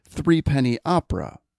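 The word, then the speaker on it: Threepenny Opera: I hope I said that correctly, but I believe it is pronounced Three Penny Opera.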